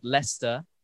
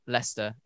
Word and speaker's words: Leicester: But if you read it, it looks like Leicester. But it's pronounced Leicester. It's one of those English words that we just try and fool everybody with. – Yeah. – By spelling it completely differently to how it's pronounced.